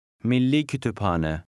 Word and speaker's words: Milli Kütüphane: The letters P and H are pronounced separately. – Milli Kütüphane.